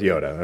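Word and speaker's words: Ioda: In Greek, the J is pronounced as a Y, and so it's Yoda, okay?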